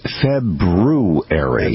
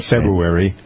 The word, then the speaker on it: February: The correct pronunciation of February, Michael. It's a test. – If I remember, it's February. – That's right. That is correct. Not February.